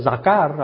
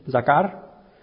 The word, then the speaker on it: zakar: Quiere decir esto, estaba diciendo esto, dice, el primer ejemplo en donde se encuentra este término, el primer ejemplo de Zakar, en el original se pronuncia Zakar, algo así, Zakar, está en Génesis capítulo 8, versículo 1.